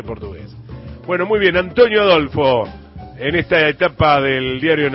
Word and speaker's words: Adolfo: En realidad Antonio Adolfo, porque los brasileños la L la pronuncian como una U.